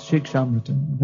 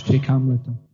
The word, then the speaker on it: Shikshamrita: That's Shikshamrita. Bengali, it's written in Bengali. Bengali pronunciation is Shikamrita.